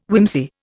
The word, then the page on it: whimsy: Indian